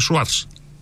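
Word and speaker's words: Soares: Έλεγα ο πρωθυπουργός είναι Σοάρες, κανείς δεν με καταλάβαινε διότι το όνομα Σοάρες προφέρεται Σουάρς.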